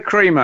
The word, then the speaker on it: Cremer: I say Cremer, as in non-dairy.